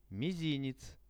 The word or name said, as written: mizinec